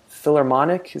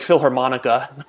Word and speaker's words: Philharmonic: So I have someone who's in one of my programs who is a world-class musician, plays for the London Orchestra, the Philharmonic. Is that? I said it wrong last time. I said like Philharmonica. It was a big laugh.